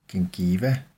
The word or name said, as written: gingiva